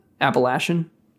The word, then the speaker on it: Appalachian: Appalachian. – Okay. I'm from Wisconsin, so you can't blame me for pronouncing things wrong with A's.